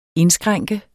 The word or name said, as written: indskrænke